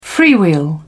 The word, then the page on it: freewheel: British English